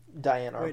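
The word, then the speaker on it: Diane Arbus: She's talking about the photographer Diana Arbus. Am I pronouncing that right? – Diana Arbus. Which, yeah.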